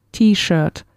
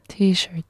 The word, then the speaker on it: T-shirt: Y T-shirt, que ya lo has conocido, pero lo pronunciamos a la inglesa, ¿no? T-shirt. – T-shirt. – ¿No? Uh-huh. Como la letra T y el shirt.